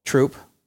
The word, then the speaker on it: Throop: So I went, I remembered the uh, the it's called Troop, spelled Throop, but spelled but pronounced Troop.